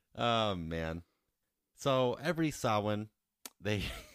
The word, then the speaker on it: Samhain: Samhain. You, like, broke my mind. – It's like saw and win put together.